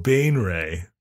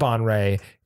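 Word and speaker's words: Baenre: Jarlaxle Bane Ray. – That's right. It has a pronunciation guide. And I found out that it's not Bon Ray.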